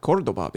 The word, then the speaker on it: Cordoba: I called it Cordoba because that's how you say it in Spanish.